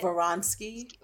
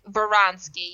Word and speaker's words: Vronsky: Vronsky. – Vronsky, yeah.